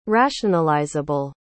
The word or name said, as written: rationalizable